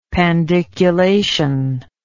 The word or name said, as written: pandiculation